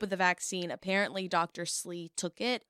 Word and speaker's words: Slee: But I want to say it's Slee, S-L-E-E.